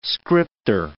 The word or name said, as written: scripter